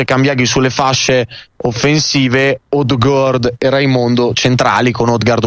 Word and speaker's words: Odgaard: Odgaard sarebbe la pronuncia corretta, poi ovvio che viene più facile dire Odgaard.